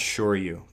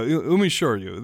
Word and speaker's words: Shoryu: I think it's pronounced Shoryu, but I could be wrong. – There we go. Umeshoryu. There we go. I'll say it correctly this time.